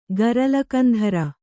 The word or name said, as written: ಗರಲಕಂಧರ